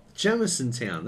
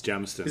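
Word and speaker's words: Jamisontown: It's Jamiston. – Is it pronounced Jamison Town? – Jamiston. – Jamiston. – No, it's not town. Jamiston.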